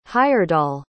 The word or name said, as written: heyerdahl